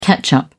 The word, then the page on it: ketchup: British English